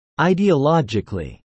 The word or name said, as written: ideologically